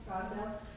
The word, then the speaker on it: Gosnell: Gosnell.